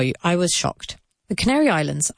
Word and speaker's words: Canary Islands: Now be careful here with pronunciation. I know it's very difficult, but it's así. The Canary, not Canaria, the Canary, Canary como el pájaro, the Canary Islands, sin la S y con la S al final, pero sin la S entre media. So the Canary Islands, land like tierra, and island, I like ojo. That's the pronunciation.